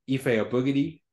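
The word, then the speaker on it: Efe Abogidi: Uh, and and you know all the power to him, but he has asked to be pronounced Ife Abogidi.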